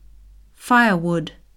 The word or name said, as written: firewood